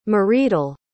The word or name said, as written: mridul